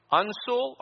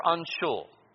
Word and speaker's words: uncial: That first word is pronounced two ways you can pronounce it. Uncial or unshul. Either way.